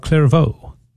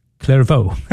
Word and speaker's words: Clairvaux: I was corrected by Cecil, so I'm going to say it correctly this time, okay? St. Bernard of Clairvaux. Clairvaux.